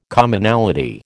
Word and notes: Commonality: American English

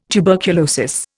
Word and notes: tuberculosis: Indian

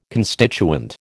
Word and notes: constituent: American English

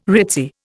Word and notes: ritzy: Indian

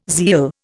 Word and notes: zeal: Indian

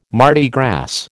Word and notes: Mardi Gras: American English

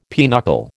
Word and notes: pinochle: American English

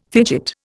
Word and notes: fidget: Indian

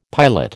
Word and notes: piolet: American English

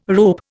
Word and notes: rope: Indian

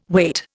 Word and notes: wait: Indian

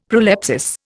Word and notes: prolepsis: Indian

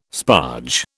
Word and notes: sparge: British English

